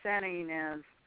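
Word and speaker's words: Santa Ynez: How do you pronounce that? – I think they say Santa Inez.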